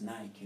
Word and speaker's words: Nike: The people who live in the locations and whatsoever, they say, this is Nike, you know, there's no matter of pronunciation. It's the same as we say Wote. And they don't know even that the brand is Nike. They call it Nike.